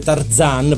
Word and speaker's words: Tarzan: La sigla finale famosissima è eh, Ma quanto è forte Tarzan, proprio con la Z, eh, scusate, con la, l'accento sulla A,